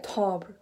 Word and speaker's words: timbre: So the word timbre, the French word timbre, also, well, that's how we would pronounce it, right? It would be like timbre.